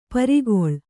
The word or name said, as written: ಪರಕಾಲ